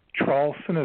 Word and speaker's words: Traulsen: name Traulsen is spelled T like Tom.